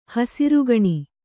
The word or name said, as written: ಹಸರಗಣ್ಣಿ